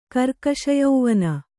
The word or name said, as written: ಕರ್ಕಶಯೌವನ